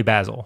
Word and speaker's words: Basil: The family tells me Basil, yeah. but I'm not going to correct Fred Minnick. – Basil Hayden is, Basil is an English pronunciation. Basil's more of an American pronunciation.